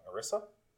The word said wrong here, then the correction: Orisa: Or, yeah. Orisa, mm-hmm.